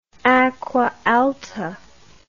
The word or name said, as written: acqua alta